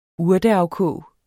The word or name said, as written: urteafkog